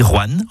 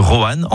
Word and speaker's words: Rouen: Vous avez remarqué comment je dis Rouen en une syllabe plutôt que Roanne en deux syllabes ? C'est comme ça qu'on y dit dans la région.